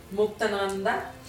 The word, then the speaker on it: Muktananda: Muktananda.